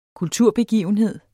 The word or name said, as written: kulturbegivenhed